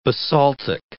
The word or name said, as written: basaltic